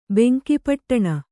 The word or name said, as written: ಬೆಂಕಿಪೊಟ್ಟಣ